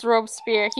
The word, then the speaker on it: Robespierre: It's Robespierre. He will guillotine you for pronouncing his name wrong.